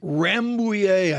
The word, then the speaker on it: Rambouillet: Rambouillet, I think I pronounced that right.